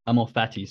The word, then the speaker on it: Amor Fati: I think Ama Forti, I always say this wrong, but Ama Forti is the Latin, Amor Fati, sorry,